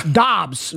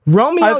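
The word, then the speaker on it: Doubs: Romeo Dubs. Oh, Dobbs. Romeo. I keep saying his damn name wrong. Romeo Dobbs.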